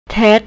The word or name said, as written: tet